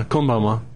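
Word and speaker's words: konbanwa (こんばんは): I know konbanwa from Kevin on Iron Chef America, where he says, is that how you say it? – Yeah, konbanwa.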